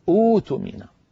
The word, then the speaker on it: اؤتمن: فأؤتمن يبدأون بها أوتمن، أوتمن يبدلون الهمزة الساكنة الثانية حرف مد من جنس الأولى. ما حركة الهمزة الأولى؟